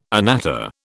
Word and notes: anatta: British English

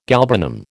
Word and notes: galbanum: American English

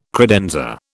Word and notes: credenza: British English

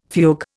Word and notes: fugue: Indian

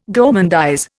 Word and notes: gourmandise: Indian

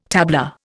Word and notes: tabla: Indian

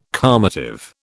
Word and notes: calmative: British English

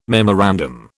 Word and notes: memorandum: British English

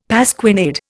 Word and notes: pasquinade: Indian

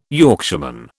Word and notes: Yorkshireman: British English

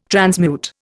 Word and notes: transmute: Indian

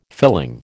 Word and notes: filling: American English

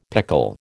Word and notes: pickle: American English

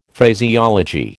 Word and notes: Phraseology: American English

